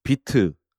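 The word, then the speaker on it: Vite: 그리고 2020년에는 비트, VITE인데 비트라고 읽죠.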